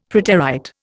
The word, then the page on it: preterite: Indian